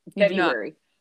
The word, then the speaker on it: February: Well, look, in my last episode, I asked the Pod Squatters, do y'all pronounce the R, the first R in February? Do you or do you not? – Say? No, I do not. February. Do not. – I don't think most people do. And I'm like, there's a whole R in there that is getting neglected. – Well, do you pronounce T-O-U-R?